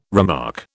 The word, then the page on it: remark: British English